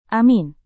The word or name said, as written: amin